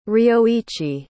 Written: ryoichi

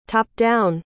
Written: top-down

